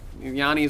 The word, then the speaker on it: Yanni: That's with a soft J, right? – There's with a Y, so there's no there's no J in it. – Oh. – Yanni is with a Y.